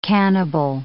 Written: cannibal

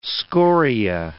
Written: scoria

